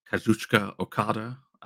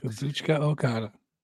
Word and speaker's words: Kazuchika Okada: Recently, AEW has a notable success signing wrestlers like Will Ospreay, Mercedes Moné, Kazuchika Okada. I make sure to say that right every single time. – Kazuchika Okada.